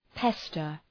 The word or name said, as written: pester